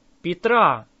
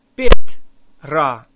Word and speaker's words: Петра: 1st and 2nd Peter is Первое и второе Петра. Петра. Say ra. Петра.